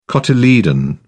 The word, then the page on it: cotyledon: British English